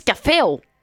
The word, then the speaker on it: Caféo: Et c'est même pas le café, c'est même pas écrit ni dit comme le café que l'on boit. Non. Ils disent « caféo ». – Ça s'écrit « C-A-F-E-W ».